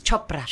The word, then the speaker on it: Chopra: Chopra.